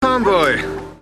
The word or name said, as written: Farmboy